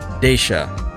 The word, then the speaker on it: Deixa: I believe it's pronounced "Deixa."